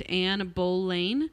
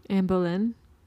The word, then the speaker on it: Anne Boleyn: There's another story that involves a woman named Anne Boleyn, and she was imprisoned Did I say that wrong? – Anne Boleyn. – Oh, Anne Boleyn.